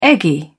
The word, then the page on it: eggy: British English